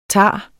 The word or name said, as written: tage